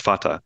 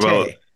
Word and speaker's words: Fateh: Yeah, a lot of people get his name wrong because they try to pronounce it the way that Somalis do, which is Fateh. But oh, really? Because I think that's what I would naturally say too, but it's right, Fateh.